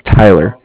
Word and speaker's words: Tyler: It's spelled, my name is Tyler, T-Y-L-E-R, and then Cardiff spelled C-A-R-D-I-S-S, as in Frank.